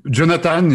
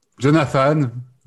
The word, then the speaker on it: Jonathan: Jonathan, yeah. Jonathan.